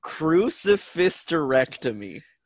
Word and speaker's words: crucifisterectomy: It's pronounced, and I know that, like, I'm pretty sure nobody will ever get this unless I say it. It's crucifisterectomy. – Crucifisterectomy. – So you take the word hysterectomy, which is removal of part of or all of the uterus.